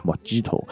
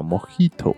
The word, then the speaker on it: Mojito: 那根据英文的发音，它本来就是叫莫吉托嘛，Mojito。但其实这样子的发音是错的，因为它其实是根据西班牙的一种啊发音方式的，是叫 Mojito 啊，很装逼。